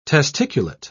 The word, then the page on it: testiculate: British English